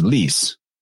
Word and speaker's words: Lys: And then you also got to throw into the mix that the Phoenician name for a lion is Lys. That's basically how it's said in the Phoenician.